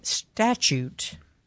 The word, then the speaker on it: statute: Statute. – Statute. – Well, statute. and there is no CH in there's, there. There's a T that is pronounced CH.